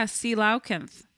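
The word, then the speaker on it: coelacanth: Yeah, Sealaukanth. That's how you pronounce it.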